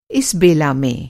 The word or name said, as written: बेला